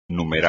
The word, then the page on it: numerar: espagnol